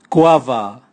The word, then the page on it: kuava: Raga